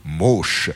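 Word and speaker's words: Moosch: Alors Mauche, cela s'écrit M2OSCH. Si vous nous suivez depuis le début de la semaine, vous l'aviez peut-être déjà noté. M2OSCH, et on dit plutôt Mauche.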